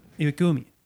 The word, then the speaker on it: Iwagumi: How's it pronounced? Iwagawa? Iwagawa? – Iwaguma. – Iwagumi. Guma. Thank you.